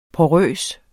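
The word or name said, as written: PORØST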